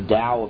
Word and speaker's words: Tao: He wrote that book, The Tao of Physics, the T-A-O, from Taoism. I don't know why we spell it with a T if you don't pronounce it that way, but that's the way it's spelled.